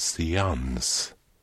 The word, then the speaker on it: seans: Det uttalas seans.